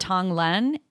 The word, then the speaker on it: tonglen: do anything okay so one is called tonglen and it is a uh, it's like a practice it's like a it's a Buddhist practice.